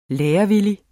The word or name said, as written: lærevillig